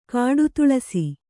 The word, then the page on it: ಕಾಡುತುಳಸಿ: kāḍu tuḷasi